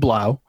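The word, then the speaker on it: Blough: It's David Blau.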